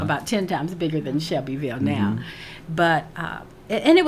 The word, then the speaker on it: Shelbyville: I don't pronounce it the way non-Shelbyvillians pronounce it. Mm-hmm. I say Shelbyville. I give it the three syllables mm-hmm. that it's supposed to have.